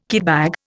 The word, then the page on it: kitbag: Indian